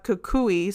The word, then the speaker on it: Kukui: And when I actually looked it up, Kukui, so it's spelled K-U-K-U-I.